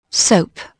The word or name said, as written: soap